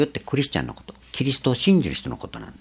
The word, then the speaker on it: キリスト: 日本だけはキという発音になる。キリストという。